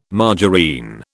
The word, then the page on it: margarine: British English